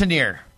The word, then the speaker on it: Tanier: Am I getting – Yeah, I think it's Tanier. – Tanier, I'm sorry, Tanier. It's spelled one way, and then it's pronounced another way, and I should know that because he's been on a number of times.